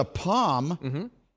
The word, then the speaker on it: palm: But the palm, the palm of your hand, palm tree, that's just no, you don't, an almond, you don't pronounce the L.